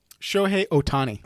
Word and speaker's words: Shohei Ohtani: Shohei Ohtani.